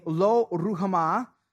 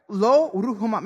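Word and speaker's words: Lo-Ruhamah: They had a daughter named Lo Ruhamah. I looked that up because I wanted to pronounce it the right way, you know what I'm saying? Right? Lo Ruhamah.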